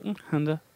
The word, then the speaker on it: Hanja: Hanja. – See, this is what happens when somebody speaks another language. You're like, Hanja? And they're like, Hanja. Yeah. You're close, but Hanja.